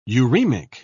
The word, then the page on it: uraemic: British English